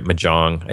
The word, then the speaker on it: Mojang: You know, it's totally unofficial, no support from, I can never say the name of the company right, Mojang, I think is how you pronounce it. Mojang is the company that makes Minecraft.